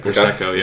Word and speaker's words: Prosecco: Prosecco, yeah.